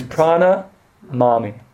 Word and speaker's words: prana mami: I left off a syllable. It's prana mami.